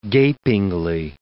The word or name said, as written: gapingly